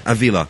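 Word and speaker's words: Ávila: Is that right? Avila? Am I pronouncing that correctly? – Avila. – Avila.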